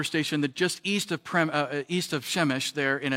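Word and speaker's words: Przemyśl: And then this one looks like Przemysl. It's actually pronounced Chemish. This is why the Polish people get a bad rap, I think. That is not Chemish. That's Przemysl.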